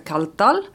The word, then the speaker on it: Kaldal: Mitt efternamn är ett isländskt efternamn. Så att därför så heter det på isländska Kalldal.